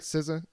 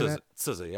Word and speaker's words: SZA: And this is the one that she did with SZA, right? SZA is how you say SZA, yeah. – SZA.